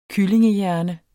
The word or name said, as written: kyllingehjerne